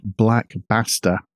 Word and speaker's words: Basta: The ransomware in question is called Black Basta, and I've got to pronounce that very carefully, B-A-S-T-A.